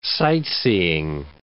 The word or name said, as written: sightseeing